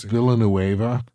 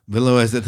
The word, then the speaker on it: Villeneuve: Visually pronounced Villeneuve. – Villeneuve. Thank you.